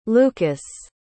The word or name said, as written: lucus